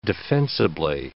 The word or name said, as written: defensibly